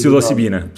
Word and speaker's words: Psilocibina: Psilocibina.